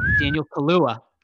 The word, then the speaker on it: Kaluuya: In other news, Daniel Kaluuya, is that how you pronounce his name? Daniel Kaluuya. All right.